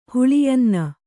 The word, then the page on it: ಹುಳಿಯನ್ನ: Kannada